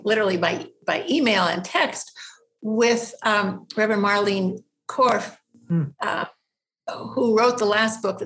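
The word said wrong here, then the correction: Kropf: I actually think I just mispronounced her name. It's K-R-O-P-F, not Korff, Krof.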